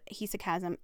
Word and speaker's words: hesychasm: The term meditation in English, it may also point to practices from Islamic Sufism or Jewish Kabbalah and Christian hesychasm, I think is what it's how it's pronounced. I looked it up and now I can't remember, but it's hesychasm.